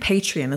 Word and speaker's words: Patreon: Patreon. Is it, you pronounce it Patreon, is it? – Patreon, Patreon.